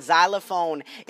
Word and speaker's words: xylophone: Or better yet, why not rename it to I-L-A-N-D? You know what I'm saying? I just don't get it. Xylophone. How the hell you going to tell me that xylophone is pronounced xylophone when you have an X? Like when you say the word extreme. Extravagant. You are literally pronouncing the word X, but yet when it comes to xylophone, which doesn't even have a Z in it, you pronounce the X like a Z.